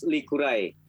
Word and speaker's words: Likurai: First question I have is the basis of your show is a traditional welcoming dance by the name of Likurai, if that's pronounced properly. – Yes, uh, it's pronounced Likurai.